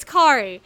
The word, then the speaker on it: Kari: It's Kari. Here's the way you know. My first anime boss, and she was a guest there, and I went to her panel. She taught us how to pronounce her name. Just think of Kari like Ferrari.